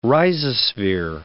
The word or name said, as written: rhizosphere